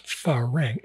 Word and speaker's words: farang: It's pharang, F-A-R-A-N-G.